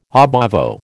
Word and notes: ab ovo: American English

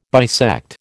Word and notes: bisect: American English

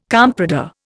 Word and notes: comprador: Indian

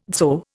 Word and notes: dzho: Indian

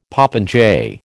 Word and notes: popinjay: American English

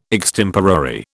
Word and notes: extemporary: British English

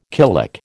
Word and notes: killick: American English

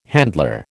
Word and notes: handler: American English

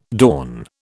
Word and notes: dawn: British English

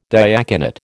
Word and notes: diaconate: American English

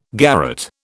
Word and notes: garrotte: British English

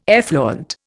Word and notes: effluent: Indian

